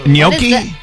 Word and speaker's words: gnocchi: Is it ganache? – Gnocchi? – Gnocchi. Gnocchi. – Gnocchi. – That's how you say it.